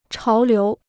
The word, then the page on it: 潮流: cháo liú